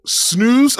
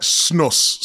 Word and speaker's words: snus: Some call it snooze and some call it snus.